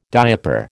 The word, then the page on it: diaper: American English